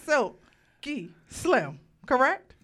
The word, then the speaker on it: Silky Slim: Mr. Silky Slim, correct? – Mr. Silky Slim, yes. – Mr. Silky. I said it. I just wanted to make sure I said it properly.